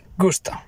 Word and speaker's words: gusto: Also, regarding gusto, I assume it's the Spanish word for taste. And in that case, it is definitely pronounced gusto. No.